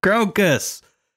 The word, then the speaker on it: Krokus: It's like a Crocus album. Permanent vacation. – Crocus. – Crocus. K-R-O-K-U-S. Crocus. – Crocus, like the flower. – Sorta, but harder.